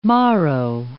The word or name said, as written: morrow